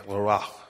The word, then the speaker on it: Leroy: Tonight, we welcome dancer and teacher Pascal Leroy, or pronounced correctly, Leroy. I'm working on my French pronunciation.